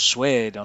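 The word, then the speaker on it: Suede: Après European Ghost, j'ai eu envie de passer un titre du nouvel album de Suède, qui se prononce Suède en anglais,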